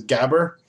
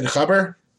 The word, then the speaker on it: gabber: But I mentioned to the person working that day that I liked a certain genre, like a subgenre of hardcore techno, which is pronounced at least incorrectly in North America as gabber. And the proprietor like sneered at me. Like, he's like, that's not how you pronounce it. I'm like, oh, I'm sorry. How do you pronounce it? I think he said khabber.